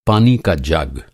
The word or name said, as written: जग